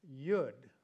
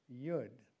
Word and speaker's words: yod: It would be spelled T-E-T-H in English, but pronounced tet. And then the next, Y-O-D, beginning just before verse 73, which is pronounced yud, rhymes with could, yud.